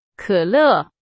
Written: 可乐

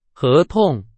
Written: 合同

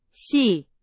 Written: 系

